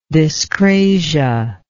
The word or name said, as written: dyscratic